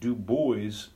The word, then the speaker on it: Du Bois: They do have a city there called Dubois. It's spelled Dubois, but they pronounce it Du Bois, and they will correct you.